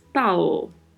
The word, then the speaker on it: tal: Quanto à pronúncia, devemos nos atentar à letra L Lembre-se, você pronuncia sempre com a ponta da língua tocando a parte superior da boca, na parte mais anterior. Então, falamos tal, tal.